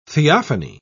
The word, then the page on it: theophany: British English